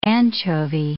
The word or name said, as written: anchovy